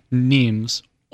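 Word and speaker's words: Nîmes: It was a town called Nimes, spelled N-I-M-E-S, like dimes, but pronounced Nimes. And they started to produce a fabric called Serger, S-E-R-G-E-S.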